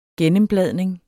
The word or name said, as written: gennembladning